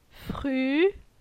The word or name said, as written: Fru